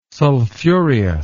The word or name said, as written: sulfureous